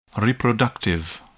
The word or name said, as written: reproductive